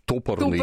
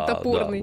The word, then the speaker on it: топорный: Мы ударение неправильно ставим. Вот сегодня она сказала топорный. – Топорный, или... – да. Вместо топорный. – Да, вот.